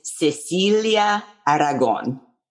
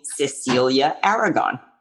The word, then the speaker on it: Cecilia Aragon: How do you say it in Spanish? – So my name is Cecilia Aragon, but you can also call me Cecilia Aragon.